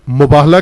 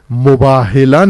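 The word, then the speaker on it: مباہلہ: بعض زیادہ ادیب بن جاتے ہیں اور اپنی طرف سے الفاظ کی تصحیح کرتے ہیں، مباہلا نہیں ہے بلکہ مباہلا ہے، پر زبر ہے، میم بے الف ہے دو آنکھوں والی لام اور گول تے، مباہلا، مفاعلا، مقاتلہ، مقاتلہ نہیں مقاتلہ، تلفظ درست کریں.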